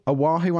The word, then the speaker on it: Oahu: O-A-H-U? Oahu? I'm pretty sure that's it. God damn, Hawaii. Come on. What the fuck, man? It's O-A-H-U and it's pronounced Oahu.